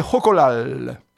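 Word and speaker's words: xocolatl: le nom aztèque c'est Jocolal.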